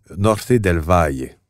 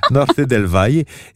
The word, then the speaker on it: Norte del Valle: Je devrais prononcer Norte del Valle. En fait. – T'as pas besoin de le prononcer comme ça. – Norte del Valle.